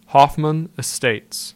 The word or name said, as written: Hoffman Estates